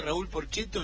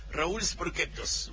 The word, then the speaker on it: Raúl Porchetto: Claro, lo pronunciaba en italiano. Y me causaba mucha gracia. Entonces cada vez que escucho a Raúl Porchetto, yo enseguida en mi mente, Raúl Sporchettos.